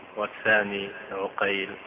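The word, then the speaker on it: عُقيل: فهنا اتفقت الأسماء واختلفت أسماء الآباء لا في رسمها وإنما في نطقها، فالأول عقيل بفتح العين والثاني عقيل بضمها، فالأول يقول الحافظ نيسابوري والثاني فريابي، وهما مشهوران وطبقتهما متقاربة.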